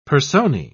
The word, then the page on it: personae: British English